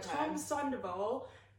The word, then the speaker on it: Sandoval: Anyway, so Tom Sandoval... Sorry, I'm really bad at pronunciation. – Well, that sounds right.